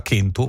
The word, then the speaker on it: kentu: tranne il sardo che la pronuncia ancora kentu, uno delle lingue della Sardegna la pronuncia kentu,